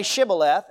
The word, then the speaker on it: Shibboleth: And the Ephraimites had never learned to pronounce that SH sound. Instead, it would come out as a plain S, a S sound. And so it was a simple test, say Shibboleth, and if they couldn't say Shibboleth, they said Sibboleth.